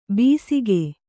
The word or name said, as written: ಬೀಸಿಕೆ